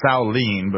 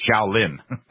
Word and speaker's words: Shaolin: It's actually pronounced Shaolin, but for everybody that's ever watched Kung Fu, it's Shaolin.